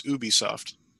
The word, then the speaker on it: Ubisoft: For the record, company president Yves Guillemot says the official pronunciation is Ubisoft.